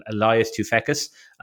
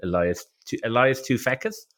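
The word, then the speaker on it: Toufexis: I loved Adam Jensen as well as a character, Elias Tufekis. Literally Googled, that's how to pronounce his name. That is actually how you pronounce it. Elias Tufekis.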